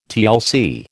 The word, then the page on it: TLC: American English